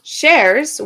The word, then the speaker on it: Shares: Shares, which is pronounced like more than one share, is an Iranian-American actor, singer, and vocal coach.